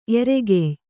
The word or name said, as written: ಎರೆಗೆಯ್